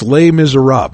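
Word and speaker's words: Les Misérables: The name of the book was Les Miserables, or if you just were reading it, it looks like Les Miserables, but it's Les Miserables. That's how you say it in French.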